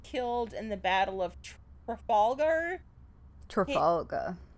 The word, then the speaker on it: Trafalgar: I think it is Trafalgar, and I have only heard it pronounced by someone with an accent that would have swallowed the R.